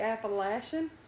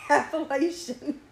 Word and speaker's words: Appalachian: And half the time I'm going to say Appalachian, and half the time I'm going to say Appalachian.